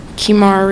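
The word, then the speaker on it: Keymaira: Kimara Reese, K-E-Y-M-A-I-R-A-R-E-E-S-E.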